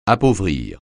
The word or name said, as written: appauvrir